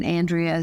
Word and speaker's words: Andrea: And Matthews is spelled with one T and Andrea is A-N-D-R-E-A.